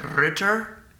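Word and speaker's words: Riitiir: It's R-I-I-T-I-I-R. – Ritter? – Oh, yeah, that's right.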